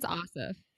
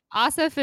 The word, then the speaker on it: Ossoff: I think it's Ossoff. Ossoff is the way that me and my family have been pronouncing it.